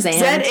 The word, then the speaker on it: Zahnd: I came across something by pastor and author Brian Zand. – Sure. – Zand? – Sure, Zand. – Z-A-H-N-D, however you would pronounce that. – Zand.